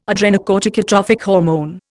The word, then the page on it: adrenocorticotrophic hormone: Indian